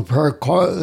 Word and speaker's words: paraquat: While I was doing some of my research, I did find that there is a lawsuit for paraquat. Am I saying that correctly? – I believe so.